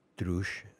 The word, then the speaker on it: Driouech: I believe that her last name is pronounced Droosh.